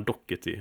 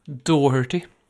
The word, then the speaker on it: Doherty: Men eh, med eh, Kom ni fram till hur man uttalar, säger man dockety eller vad säger man? – Doherty. – Doherty, mm. ja.